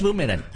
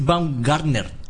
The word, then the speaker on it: Baumgartner: Félix Boomerang. Félix Baumgartner.